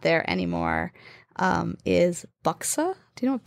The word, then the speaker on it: buxar: B-U-X-A-R. But you don't pronounce the R. So it's like buksa.